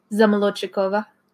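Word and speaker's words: Zamolodchikova: Zamolochikova.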